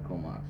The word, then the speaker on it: Comox: Comox tribes are called by. – Sasholt. – Alright, and then this is the Cape Mudge pronunciation for Comox? – Comox.